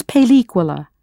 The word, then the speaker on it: película: As you know, the word for movie or film in Spanish is película, spelled P E L I with an accent, C U L A.